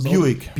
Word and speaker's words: Buick: Buick.